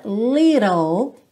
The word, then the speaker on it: little: Essa palavra little, pensa que esse T fica rarara e você interrompe o som no L, ok?